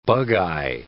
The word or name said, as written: bugeye